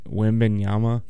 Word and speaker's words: Wembanyama: Can you pronounce his last name? – Wembanyama. – Yeah, you did it right.